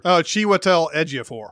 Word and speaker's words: Chiwetel Ejiofor: And then could one of you pronounce the villain actor's name so I don't look stupid? It's Etifor or whatever. – Chiwetel Ejiofor.